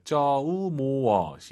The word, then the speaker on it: 周末: 주말을 중국어로 쪼모가 아니라 오유는 어우예요. 쪼우모어 시작.